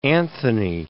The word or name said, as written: Anthony